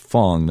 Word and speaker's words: Fang: Last name is spelled F-A-N-G, but in Chinese they pronounce it Fong, not Fang.